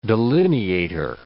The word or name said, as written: delineator